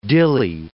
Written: dilly

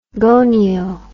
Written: gonio

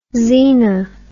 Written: zena

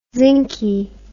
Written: zinky

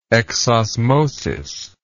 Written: exosmosis

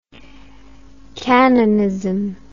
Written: cannonism

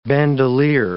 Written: bandolier

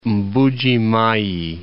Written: Mbuji-Mayi

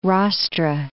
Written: rostra

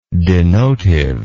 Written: denotive

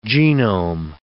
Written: genome

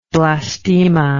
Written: blastemic

